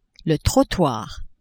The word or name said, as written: trottoir